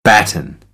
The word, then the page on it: batten: American English; ɾ